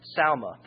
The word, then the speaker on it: Salma: Phares begat Hezron, and Hezron begat Ram, and Ram begat Amminadab, and Amminadab begat Nahshon, and Nahshon begat Salma. That's how it's pronounced.